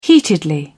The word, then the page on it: heatedly: British English